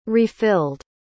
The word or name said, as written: refilled